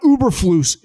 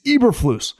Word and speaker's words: Eberflus: It's not Uberflus. It's Eberflus.